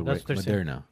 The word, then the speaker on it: Moderna: Moderna. – Okay. – I mean, it's spelled differently with every single person, but it's Moderna.